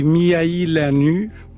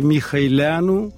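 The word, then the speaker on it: Mihăileanu: Donc en français, on dit Mihailanu, parce qu'il y a un U. En roumain, c'est Mihailanu.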